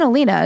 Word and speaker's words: Aleena: Even Alina, it's annoying. My name could not be more phonetic.